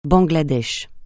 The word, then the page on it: Bangladesh: francais